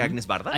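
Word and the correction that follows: Agnès Varda: Añez Barda. – Añez Barda.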